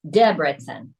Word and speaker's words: Debrecen: The emphasis is always on the first syllable in Hungarian. Tiglash. Debrecen.